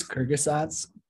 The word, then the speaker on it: Kurzgesagt: And this uh, stat's quoted in how do you say the name Kurz Kurzgesagt? – I think it's Kyrgyzats, but there you go.